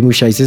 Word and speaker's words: Mushaisa: Y mis redes, pues la, de Mushaiza es Experiencia Mushaiza y Mushaiza se escribe M-U-S-H-A-I-S-A.